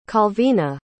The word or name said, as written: calvina